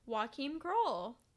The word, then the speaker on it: Joachim Kroll: But the person that we're doing is Joachim Kroll.